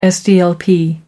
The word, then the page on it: sdlp: American English